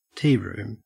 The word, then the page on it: tearoom: Australian English